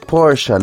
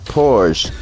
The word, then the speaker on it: Porsche: It's pronounced Porsche, not Porsche.